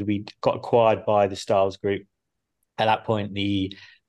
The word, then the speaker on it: Stahls: Even saying Stahls wrong. I'm saying Stahls instead of Stahls.